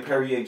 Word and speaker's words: Perrier: S H O N Perrier, just like the water.